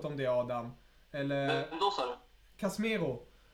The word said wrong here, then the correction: Casemiro: Eh, spanska inlånare spelaren. Eh, Casemiro. – Casemiro, tack så mycket. Jag kan inte uttala namnet. – Casemiro.